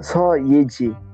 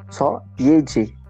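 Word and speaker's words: Seo Yeji: Uma atriz que eu recomendo muito é a Só Yeji. Só C-S-E-O, tá? É assim que é a pronúncia coreana. S-E-O. Só Yeji. Com Y-E-J-I.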